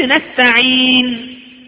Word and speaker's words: نستعين: نقوم بضم الشفتين بعد الانتهاء من الكلمة والوقوف عليها بالسكون، يعني أنت ستنطق كلمة نستعين وتقف عليها بالسكون ثم تقوم بضم الشفتين تقول نستعين، أنا قلت نستعين، وبعد ما انتهيت من تسكين النون مباشرة ضممت شفتي إلى الأمام، ضممت شفتي إلى الأمام نستعين، فأنت سماعا لن ترى فرقا، لكن رؤية سوف ترى شفتاي قد مدتا إلى الأمام، وهذا هو الإشمام.